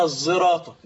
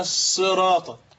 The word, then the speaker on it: الصراط: بإشمام الصاد زاي كما علمنا شيوخنا تنطق كما ينطق المصريون الضاء خطأ، فتقول اهدنا الزراطة من غير رخاوة الضاء هكذا. وقرأ الباقون بالصاد اهدنا الصراط، فصار عندنا في لفظ الصراط ثلاث قراءات.